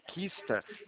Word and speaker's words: Qista: Aí tem mais um banco também, Lucas, se você quiser adicionar, o Banco Quista. Ele se escreve é Q-I-S-T-A. É um Q mudo, Q-I-S-T-A.